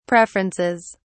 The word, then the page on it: preferences: British English